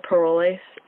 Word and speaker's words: Parolles: When we heard it in play, they said Parolles and Lasso. That's how, when I looked it up, it said like the British normally pronounce it.